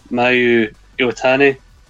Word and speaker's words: Mayu Iwatani: Mayu Iwatani. Is that how you pronounce it? – Yeah, spot on.